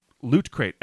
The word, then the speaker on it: LootCrate: Loot Crate, LootCrate. – Oh, loot. Like the loot. – Yeah, like loot.